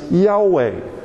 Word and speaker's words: Yahweh: And we pronounce those four consonants Yahweh.